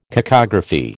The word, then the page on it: cacography: American English